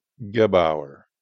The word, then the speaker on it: Gebauer: Linda Sue Gebauer, and that's spelled G-E-B-A-U-E-R, Rantoul.